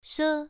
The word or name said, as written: she1